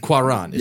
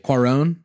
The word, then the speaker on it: Cuarón: Cuarón is what we're going to say. – Cuarón. – How we're going to pronounce it for Two Chunks and a Hunk.